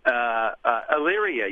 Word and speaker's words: Elyria: And you're with us from Elyria, Ohio. Am I pronouncing that correctly? – Elyria, yes.